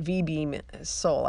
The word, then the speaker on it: Vibram: And yes, and that is how you say it, V-beam sole. I looked it up, which is spelled Vibram, just in case you care.